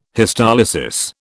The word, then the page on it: histolysis: British English